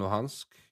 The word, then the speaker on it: Luhansk: and they will lose Donetsk and Luhansk, if that's how you pronounce it. – And, yes, it is.